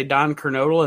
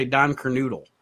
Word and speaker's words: Kernodle: There isn't a definitive pronunciation of his name because half the wrestlers would say Don Kernodal and the other half would say Don Kernoodle.